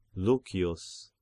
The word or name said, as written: Lucius